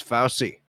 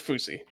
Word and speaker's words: Fousey: I thought it was Fousey. – No, retard. It's Foosy.